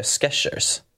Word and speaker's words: Skechers: alltså ni får ursäkta mig för uttalet men jag tror man säger Skechers. Många vill ju säga ah. Skechers men det är ju inget T i Skechers.